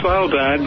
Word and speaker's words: Svalbard: How do you pronounce that? – Well, actually, the Norwegians call it Svalbard, but I think it's better known as Spitsbergen in the English-speaking world.